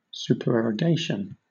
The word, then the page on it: supererogation: British English